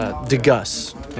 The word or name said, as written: Brett deGeus